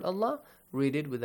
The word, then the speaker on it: الله: The word Allah is pronounced with an a sound. – Yes. – يستغفر الله يجد الله. Alright, so we do not pronounce it with an R sound.